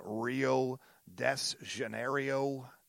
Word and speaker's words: Rio de Janeiro: Right now, I am here in Rio de Janeiro.